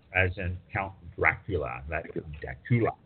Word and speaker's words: Dacula: Or Dacula? How do you say that? Dacula. Okay.